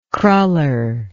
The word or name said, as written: cruller